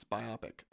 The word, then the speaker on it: biopic: And why am I saying biopic? It's biopic. I always keep saying it wrong.